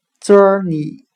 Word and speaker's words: journey: 今天我们来学习单词 journey，j o u r n e y，表示旅行的含义。我们用谐音法来记忆这个单词 journey，它的发音很像汉语的折你，折磨的折，你自己的你。